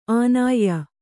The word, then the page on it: ಆನಾಯ್ಯ: ānāyya